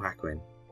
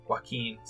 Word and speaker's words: Joaquin: Is it Jacqueline or Hackwin? – Joaquin. Sorry, Joaquin. Joaquin Phoenix.